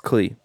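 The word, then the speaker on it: Kley: the only three real people you need to know about this is a character named Kyle, who's played by Channy Cleve. – No, it's K-L-E-Y. It's Clee.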